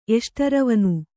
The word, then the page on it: ಎಷ್ಟರವನು: kannada